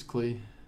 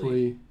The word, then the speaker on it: CLE: This is Klee. – Klee. Usually Klee. – Klee. Interesting. – I don't know. I never say it, but I always spell it. It's always CLE. – I always say CLE. – I always said Klee.